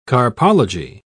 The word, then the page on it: carpology: British English